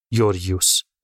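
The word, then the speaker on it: یوریوس (Γεώργιος): پسری که اسمشو گذاشتن یوریوس که چون جونم در میاد بخوام هر دفعه درست تلفظش کنم همونطوری که نوشته میشه صداش میکنم یعنی بهش میگم جورجیوس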